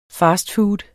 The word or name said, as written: fastfood